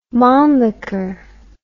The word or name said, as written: mannlicher